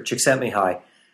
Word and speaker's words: Csikszentmihalyi: So I'll start out by saying that Professor Csikszentmihalyi, whose name we can pronounce only because he put the phonetic spelling of it on the back of his books as a kindness, because I would have never guessed.